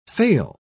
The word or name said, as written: fail